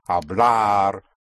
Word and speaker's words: hablar: And we'll say one track are the R verbs, meaning AR, pronounced R. Hablar, comprar, preparar. They always end in R. Yes, the AR is R.